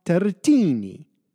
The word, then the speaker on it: Turretini: Francis Turretin, well, that's how we English speakers pronounce his name, but it is better said François Turretini.